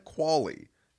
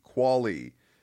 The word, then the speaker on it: Qvale: Your right tackles currently on this roster are Ty Sambrello, Kendall Lamb, and the pronunciation, so it's Brent Qualley, spelled Q-V-A-L-E. Qualley is the backup right tackle.